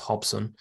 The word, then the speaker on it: Robson: Or Robson, as it's spelled, but pronounced Hobson, has been decent for me.